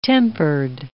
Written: tempered

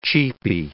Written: cheapie

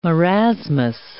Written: marasmus

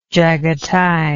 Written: Jagatai

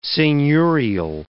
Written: seigneurial

